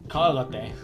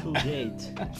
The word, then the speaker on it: Colgate: Cálgate. Se pronuncia cálgate. ¿Cómo? Cálgate. ¿Por qué cálgate? Esta pasta de dientes. Ah, Colgate. Se dice colgate, pendeja.